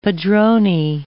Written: padrone

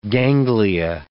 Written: ganglia

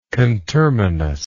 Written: conterminality

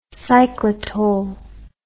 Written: cyclitol

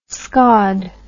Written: scaud

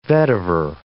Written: vetiver

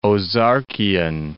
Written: Ozarkian

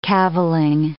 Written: cavilling

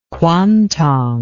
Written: quantong

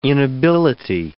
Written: inability

